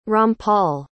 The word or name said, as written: rampal